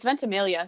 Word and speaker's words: Ventimiglia: Yeah, I think it's Ventimiglia. It's Ventimiglia. You don't pronounce the G.